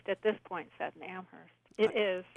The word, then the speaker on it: Amherst: No H sound. Amherst. – Amherst.